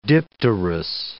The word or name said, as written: dipterous